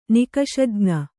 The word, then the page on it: ನಿಕಷಜ್ಞ: nikaṣajña